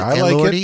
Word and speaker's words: Elordi: Elordi? Is that Elordi? How you say it? – Elordi.